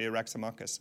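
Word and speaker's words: Eryximachus: Is it Raximachus, I think? But in French, it's Eraximach. So it must be Eraximachus in English.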